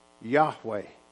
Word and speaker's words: Yahweh: It's pronounced Yahweh. Y-A-H-W-E-H.